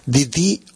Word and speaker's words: Ndidi: El nombre de la señora se escribe N-D-I-D-I-D-D-O. Si lo buscan en internet, escriben eso. N, ¿no? O sea, N no se pronuncia. N-D-D, ¿no? D-D-O-N-U-K-U-L-U.